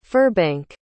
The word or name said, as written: Firbank